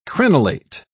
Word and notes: crenellate: British English

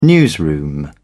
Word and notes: newsroom: British English

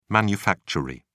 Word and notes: manufactory: British English

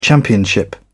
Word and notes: championship: British English

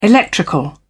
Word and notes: electrical: British English

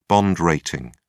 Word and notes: bond rating: British English

